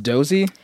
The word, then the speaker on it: Doose: Do you think it's weird that it's spelled like D-O-O-S-E and it's pronounced Dozie?